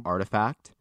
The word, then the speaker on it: RTFKT: So Nike is extending its reach into the metaverse by acquiring, so it's spelt RTFKT. It's pronounced Artifact, which is kind of a cool way to spell it.